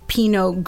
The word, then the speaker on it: Pinot: Yeah, and there's some silent letters here. It's Pinot is P-I-N-O-T. Grigio is G-R-I-G-I-O. – Right. So you don't say the T with the Pinot. – Right. – It's not Pinot. Pinot Grigio.